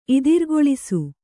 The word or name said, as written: ಇದಿರ್ಗೊಳಿಸು